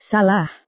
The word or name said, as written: salah